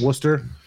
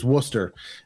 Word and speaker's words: Worcester: But those people up in Boston call it Worcester. – Worcester. – So it's kind of in between, like, Treehouse and Boston, right? There's Worcester.